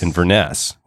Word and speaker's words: Inverness: Inverness. We got to pronounce the syllable in the right way. So Inverness. So emphasize the ness part.